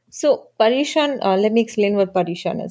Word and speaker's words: Paryushan: Paryushan? – Yeah. – Is that how you pronounce it? – Yeah. You got it.